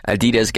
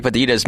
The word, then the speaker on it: Adidas: Is it Adidas or Adidas? I've been getting a lot of grief for my pronunciation of that company. – Adidas. – Yeah. Just pronounce it in a German accent. – Adidas. – Adidas. – Yeah. I've never heard Adidas. – I think it's a European thing.